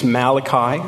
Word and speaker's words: Malachi: The pronunciation we usually use for this is Malachi.